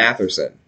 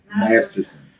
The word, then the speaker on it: Masterson: Danny Masterson. Is that how you say it, or is it yes. Matherson? – Masterson.